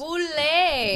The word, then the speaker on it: Boulé: There's the L is not pronounced. – Boulé. – It's the Boulé.